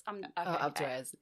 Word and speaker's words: Alpe d'Huez: I never say it right. Alpe d'Huez? – Alpe d'Huez. Yeah, thank you very much.